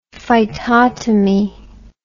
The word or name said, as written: phytotomy